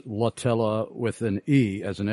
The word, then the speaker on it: Emily Latella: And we have a listener in Alabama who is correcting me on the pronunciation of, I thought I was saying Emily Latella. What was I saying? But it's Latella, according to this this listener.